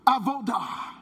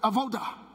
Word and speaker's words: Avodah: Avodah. Everybody say that. Avodah. All right, now you got to say it like you kind of from over there. Kind of Avodah.